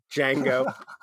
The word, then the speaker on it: Django: But yeah, I think Django Unchained. Iago Unchained. I can't pronounce it. – Django. Iago. That's a totally different movie.